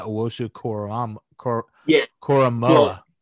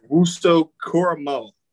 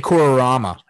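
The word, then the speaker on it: Owusu-Koramoah: Owosu-Koramoah. – I want to say Kororama every time I see it.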